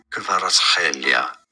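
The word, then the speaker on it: Kvaratskhelia: It sounds like Hvicha, Hvicha, Kvaratshelia, Kvaratshelia.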